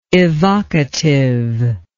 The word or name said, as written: evocativeness